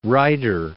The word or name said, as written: Ryder